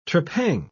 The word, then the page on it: trepang: British English